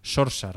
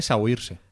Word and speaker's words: Saoirse: Ronan, pues parece que se pronuncia Sorsa ¿Tú, ¿Tú, no lo sabías? – No. – En realidad. – Yo siempre la llamé. Siempre la llamé Sawirse.